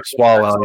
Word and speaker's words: Swalwell: Oh, I mean, like the story that like that pops up to me, like that came out recently, the Eric Swalwell, is that how you pronounce it? – Yeah, Eric Swalwell.